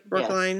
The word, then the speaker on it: Brookline: Am I saying that right? Brookline? Yes. Yes.